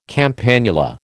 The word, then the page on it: campanula: American English